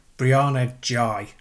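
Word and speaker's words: Brianna Ghey: And the Brianna Guy thing. Brianna Jai, I believe it's pronounced.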